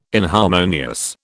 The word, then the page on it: inharmonious: British English